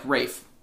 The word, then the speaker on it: Ralph: I've stopped pronouncing the L. Like Rafe. Like Raphael. – Rafe.